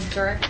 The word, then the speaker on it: Durick: I'm Alexander Mosry on the research team at the FCIC, and the last name is M-A-A-S-R-Y. – I'm Katie Durick with Sidley Austin. Last name is spelled Durick, D-U-R-I-C-K. – Mark Hopson from Sidley Austin, representing Dick Siren. It's Hopson, H-O-P as in Paul, S-O-N.